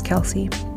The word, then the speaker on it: Kelsea: My name is Kelsea. K-E-L-S-E-A.